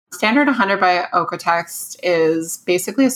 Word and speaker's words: OEKO-TEX: We talked about, I don't know if you, do you say it or do you like say in each letter, the O-E-K-O-T-E-X? – Yeah, it's pronounced apparently Ercotext, which is not intuitive, but yes. Ercotext, yeah. – That's like not what it looks like. – I know. I was pronouncing it wrong for years. And then I met them at a trade show and they like said Ercotext.